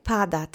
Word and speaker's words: pada: Let's say pada together. Accent is on the first syllable.